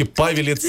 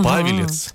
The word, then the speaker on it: Павелец: Вокзал так назван из-за ветки Павелец, Москва. – Понятно. – Именно такое ударение, ага. Павелец, да, чтобы вы знали, именно там она проходила.